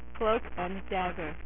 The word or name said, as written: Cloak and Dagger